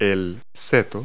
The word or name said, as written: hedge